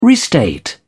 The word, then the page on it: restate: British English